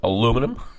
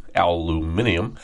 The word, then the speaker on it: aluminium: And aluminium. Oh, I'm sorry. Aluminum. Aluminium. I mean, there's two ways to pronounce that.